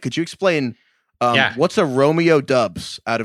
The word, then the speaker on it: Doubs: it had been saying it Romeo Dubs, like a W. – It's D-O-U-B-S is the spelling, D-O-U-B-S.